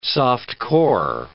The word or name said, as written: soft-core